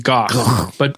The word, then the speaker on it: Gogh: This is one of my frustrations with Van Gogh is that he's Dutch. And so the way you pronounce that name is Gogh, but because like France was the center of like art and culture at the time, they mispronounced his name and that became the dominant pronunciation.